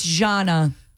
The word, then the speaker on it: Gianna: It's not a hard J. It's not Gianna. It's Gianna. – Like genre without the R. – Okay. – Or like Zsa Zsa Gabor, if that's your flavor. Or like Elijah, like the name Elijah, J-A-H.